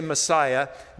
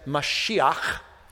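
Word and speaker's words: Mashiach (משיח): And by the way, the Jews didn't pronounce it like we pronounce it. They didn't say Messiah. They said Mashiach.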